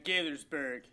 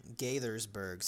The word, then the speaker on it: Gaithersburg: So October 9th, 2002, same day, Dean Harold Myers, 53, of Gaithersburg, Maryland. I think I said that right. It looks right. – It looks right to me, man. Gaithersburg. – Gaithersburg. Sounds good. Looks like it's spelled. I'll take it.